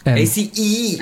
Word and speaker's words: Ace: Aber im Englischen ja ausgesprochen, denn. – ACE.